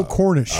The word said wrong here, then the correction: Cornrich: Yeah, it's, well, I'm saying it wrong. Neil Cornrich. Cornrich.